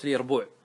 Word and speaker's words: اليربوع: ثم فاعلاء مثل قاصعاء، جحر من جحرة اليربوع، واليربوع بالياء وليس بالجيم كما ينطقه بعض الناس.